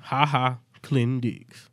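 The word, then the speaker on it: Ha Ha Clinton-Dix: Ha-Ha Clinton Dix.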